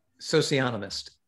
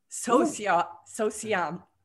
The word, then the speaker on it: socionomist: He is something called an economist. Not an economist. I'm saying it wrong, right out of the gate. Say it for me. – Socionomist. – Socionomist. See, it's really hard to pronounce because I have all these other words in my head.